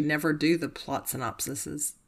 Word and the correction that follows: synopses: I'm not saying that right. The plot synopses. – Synopses? – Yes. Synopses. I would say Bartok and Zosie is his friend.